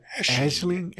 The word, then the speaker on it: Aisling: Ooh, Aisling. She says Aisling. Yeah, even though it's not spelled anything like that.